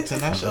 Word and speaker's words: Tinashe: Tinashe? I don't know how to say it. – Tinashe? – Yeah. – The singer? – The Tuon girl. – That's what she was trying to say, Tinashe.